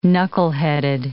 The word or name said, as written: knuckleheaded